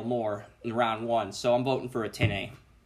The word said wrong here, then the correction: Etienne: Have we all been mispronouncing his name like, for 3 years? – Etienne. – Travis Etienne. – Etienne. – Okay. – That's my thing. – Etienne. It's ETN.